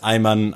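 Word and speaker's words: Ayman: Ich, also A, Y und Mann. Ich glaube, so sollte die Schreibweise sein. Vielleicht auch irgendwie so ein bisschen arabischer angehaucht, aber prinzipiell hätte man es Eimann ausgesprochen.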